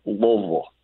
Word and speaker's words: Louisville: Hey, Jamie, I do have one quick question, but Hawk, it's pronounced Louisville, just so you know.